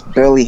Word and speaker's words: Burleigh: And then it's Burley Heads, BURLEIGH.